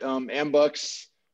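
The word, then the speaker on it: AMBUCS: but tell us about Ambux, unless I'm pronouncing that wrong, because I've only seen it spelled. – No, you said it right. Ambux, it's a nationwide organization, started in the 20s.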